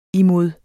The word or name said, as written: imod